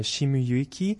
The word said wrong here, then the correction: Shimizu: Euh, est-ce que vous pouvez nous en... Oui, j'ai peut-être mal prononcé son nom. Est-ce que vous pouvez nous en dire un mot? Yasuaki Shimizu. Oui, ben en fait, c'est un, un jazzman japonais très très connu.